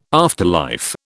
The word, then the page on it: afterlife: British English